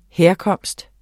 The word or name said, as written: herkomst